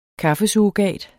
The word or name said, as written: kaffesurrogat